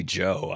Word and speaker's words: Zhou: I guess like the Zhou. – No, so that's not how you pronounce it. He wants everyone to pronounce it like Joe, though.